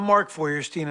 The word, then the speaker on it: Feuerstein: Hi, I'm Mark Feuerstein, and I play Dr. Hank Lawson on Royal Pains.